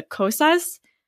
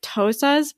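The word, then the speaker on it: Xhosa: I tried to learn how to say it with the click sound, where it sounds a little bit more like Tosas, but I can't do it, and I didn't want to butcher it.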